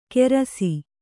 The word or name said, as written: ಗೆರಸಿ